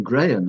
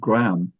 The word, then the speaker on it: Graham: Well, my name, Graham, is one which causes a lot of problems to other people. I don't know how Americans pronounce it, Graham. On the whole, foreigners cannot say it. They say Graham.